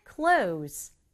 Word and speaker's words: clothes: 그럴 때 쓰는 말 clothes, clothes. 중간에 있는 th 발음하지 않아도 괜찮아요. clothes라고 해도 모두 다 듣고 이해할 수 있어요.